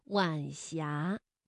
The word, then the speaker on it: 晚霞: 晚霞是晚和阳平字相连，声调呢读二一调，晚霞。